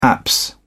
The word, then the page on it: apse: British English